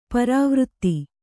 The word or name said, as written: ಪರಿವೃತ್ತಿ